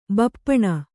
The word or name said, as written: ಬೆಪ್ಪನೆ